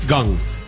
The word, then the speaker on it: Kang: I don't know if you saw this story about Pittsburgh Pirates third baseman Jung Ho Kang. – Gung. Pronounced Jung Ho Gung. – Yes, that is correct.